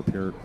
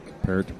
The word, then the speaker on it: Peart: This is the Neil Peart. Parrot. It's not pronounced Parrot. He corrected everybody in his book. Everybody pronounces his name wrong. It's Peart.